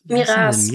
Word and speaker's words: Miras: Und ähm, der taucht später, wenig später dann direkt wieder auf, weil ähm, Untergebene von Miras ihn Miras, Midas, Minas? – Miras. Miras. Miras. Miras. Ah, Miras. Ja. – Danke, da war die Betonung.